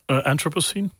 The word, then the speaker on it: Anthropocene: Zeg ik het goed zo? Of hoe moet ik het uitspreken? – Anthropocene. – Anthropocene, dankjewel. Ja.